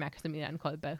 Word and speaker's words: Maximilian Kolbe: The Silver Spring Church is dedicated to Our Lady Queen of Poland and Saint Maximilian Kolbe, who is, you know, also great and also a Polish saint. – is that the Polish pronunciation of Saint Maximilian Kolbe? – Yes.